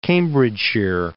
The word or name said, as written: Cambridgeshire